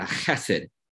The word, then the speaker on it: chesed: If you were to pronounce the word H-E-S-E-D and add a hard K or a hard C-H sound, that's what you would get, a chesed.